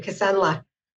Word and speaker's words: Kisunla: Did I say that correctly? Kisunla. – Good morning. Yes, you did. Thank you. Kisunla.